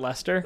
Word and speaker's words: Leicester: Why do you spell it Leicester, but pronounce it Lester? – Leicestershire, Leicester.